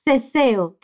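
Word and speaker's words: ceceo: Se escreve assim, S-E-S-E-O. Fala, seseo. E a outra é que existe também o ceseo, que se escreve com C, C-E-C-E-O,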